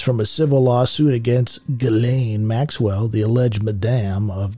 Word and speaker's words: Ghislaine: My God, it's not Ghislaine. It's Ghislaine. Half the name is silent.